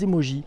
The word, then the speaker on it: emoji: les emojis, à prononcer emoji.